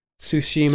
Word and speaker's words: Tsushima: Ghost of Tunisia. Am I pronouncing that right? – Tsushima. – Tsushima. I keep looking at it and reading it wrong.